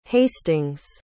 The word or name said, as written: hastings